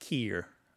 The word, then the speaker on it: care: Another thing to point out about this is that the care is often pronounced keer. I don't care. – Yeah. – Like that. – Mm-hmm. – And that's a real lovely local way of saying care.